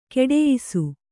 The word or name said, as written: ಗುಟಾಯಿಸು